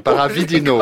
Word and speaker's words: Paravidino: Je ne comprends pas que ce pareil texte, même s'il a bonne réputation, l'auteur, hein, Fausto Padovino, là... – Paradivino, si vous voulez bien. Paravidino. – Paravidino. – Paravidino, si vous voulez enlever une syllabe.